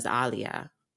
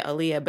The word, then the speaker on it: Aaliyah: So my name is properly pronounced as Aaliyah, mm-hmm. if you want to get like real with it. But mm-hmm. we say in the broad American, right? Yeah. We say Aaliyah, but even in my household, we say Aaliyah.